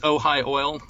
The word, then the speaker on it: Ojai: Is that how you pronounce it? – Yeah, just Ojai Oil.